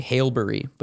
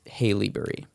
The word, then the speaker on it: Haileybury: because you want to say Haileybury, but it's Haileybury.